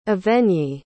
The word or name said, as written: evegny